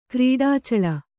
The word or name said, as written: ಕ್ರೀಡಾಚಳ